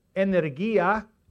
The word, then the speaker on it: energia: It's ischus, I-S-C-H-U-S. Ischus, or ischus. If you want to pronounce it really Greek, iskous. And then energia, where we get the word energy from, E-N-E-R-G-I-A, energy, energia. I'm letting you scribble. Dunamis, D-U-N-A-M-I-S.